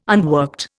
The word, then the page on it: unworked: Indian